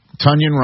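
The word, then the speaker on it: Tonyan: They got Robert Tunyon, rhymes with Funyon, to go along with the wagon, Darnell Washington, and of course, Freiermuth.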